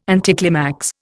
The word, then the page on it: anticlimax: Indian